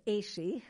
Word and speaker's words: AASHE: So AASHE, we pronounce ASHE, which is a little easier than I pronounced it ASHE when I first read it. – Well, but two vowels make a long sound.